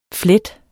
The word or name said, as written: flet